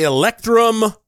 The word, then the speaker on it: Electrum: edition of the show, the Electrum Ring.